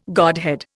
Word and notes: godhead: Indian

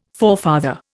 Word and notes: forefather: Indian

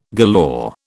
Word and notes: galore: British English

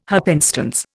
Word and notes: happenstance: Indian

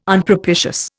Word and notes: Unpropitious: Indian

